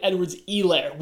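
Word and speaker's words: Edwards-Helaire: Sorry, Clyde Edwards Helaire.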